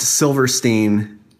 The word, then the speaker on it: Silverstein: In my professional opinion, it's pronounced Silverstein. Despite the spelling, you are so right, she says.